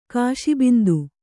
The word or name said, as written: ಕಾಶಿಬಿಂದು